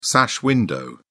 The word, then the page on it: sash window: British English